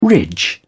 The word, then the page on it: ridge: British English